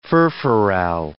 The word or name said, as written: furfural